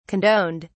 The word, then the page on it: condoned: British English